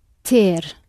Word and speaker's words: ter: It does come from the Latin tenere, but that n in the middle has dropped out. So it's ter.